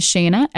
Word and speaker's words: Shana: So Instagram is Shana, S-H-A-N-A underscore DeAndrea, D-E-A-N-D-R-E-A.